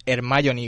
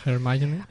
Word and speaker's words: Hermione: Para empezar, Llevamos 20 años pronunciando mal su nombre ah, porque este personaje se, se llama Hermione Granger. Hermione.